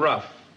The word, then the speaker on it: rough: Spelled the same way as the other. O-U-G-H. – That's right. – Made his hands strong and rough.